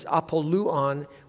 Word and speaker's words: Apollyon: And the correct pronunciation of Apollyon is Apolluon, which means destroyer.